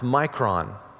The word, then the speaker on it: mikron: That phrase that we have in English and the ESV, a little while, is taken from one word in the Greek, which is mikron, which we would pronounce micron.